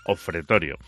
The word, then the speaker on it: Ofretorio: El último álbum se titula Ofretorio, no lo he dicho mal. Es como lo de cuando aquella película de Álex de la Iglesia de Crimen Perfecto, ¿te acuerdas? Pues lo mismo, es Ofretorio.